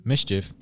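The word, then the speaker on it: MSCHF: Mischief. Well, that's how it's pronounced. – My fault. Mischief.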